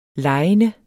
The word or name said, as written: legende